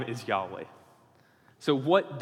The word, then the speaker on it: Yahweh: However, almost every modern scholar believes that the best guess for the pronunciation of the name is Yahweh. And this mostly comes from early Greek writings that predate the vowels that were put into the Hebrew manuscripts in like the 600s AD. This goes back to like 1st, 2nd century AD. And these Greek early Christians were saying the personal name of God is pronounced Yahweh.